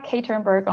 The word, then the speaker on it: Katernberg: So I'm Eva Katernberg on LinkedIn.